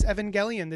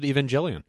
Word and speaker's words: Evangelion: Is that a hard G? Is that how they pronounce it? I always pronounce it Evangelion. – I don't pronounce it. Evangelion. – How about that? You don't pronounce it. – No, I don't. – Well, I'm going by how the dub does it.